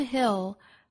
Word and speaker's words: hill: 第一句话 ,from hill to hill, no bird in flight. 我们注意深 hill 这个词的发音。松元音 i 后面跟舌侧音 ill, 再加前面的辅音读成 hill, 一定要注意舌侧音不能丢，中间的元音呢也不能发得过紧，发成了 hill, 应该是 hill,